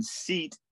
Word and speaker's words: CEAT: And Seat is spelled C-E-A-T, not S-E-A-T.